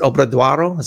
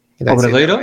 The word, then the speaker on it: Obradoiro: But when I went to the cathedral, I was sitting in the, how do you pronounce it? Obradoiro? Is that how you pronounce it? – Obradoiro? Right? Yeah, the plaza.